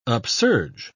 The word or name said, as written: upsurge